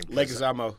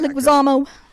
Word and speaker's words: Leguizamo: Leguizamo. – Yeah, Leguizamo.